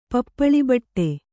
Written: ಪಪ್ಪಳಿಬಟ್ಟೆ

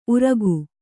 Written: ಊರುಗ

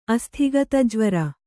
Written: ಅಸ್ಥಿಗತಜ್ವರ

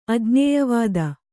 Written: ಅಜ್ಞೇಯವಾದ

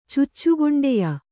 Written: ಚುಚ್ಚುಗೊಂಡೆಯ

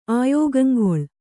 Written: ಆಯೋಗಂಗೊಳ್

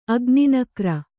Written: ಅಗ್ನಿನಕ್ರ

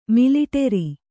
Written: ಮಿಲಿಟರಿ

